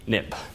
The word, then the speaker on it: XIB: But you pronounce XIB NIB.